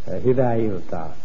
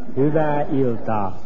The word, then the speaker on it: Hyvää iltaa: Tell me, how do you say good evening in Finnish? – Hyvää iltaa. – Give me that again. – Hyvää iltaa. – Hyvää iltaa. – That's right. – Hyvää iltaa.